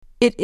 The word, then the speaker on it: it: It is. It. It is. Yes, it is here. – به تلفظش دقت کنید. It نباید بگید. یه صدای بین ای و ای.